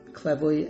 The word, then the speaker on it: Clairvaux: What I learned after a great expert, Bernard of Clavoy, that Clairvoy is how you pronounce it, uh, C L A I R V A U X,